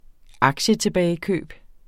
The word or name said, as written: aktietilbagekøb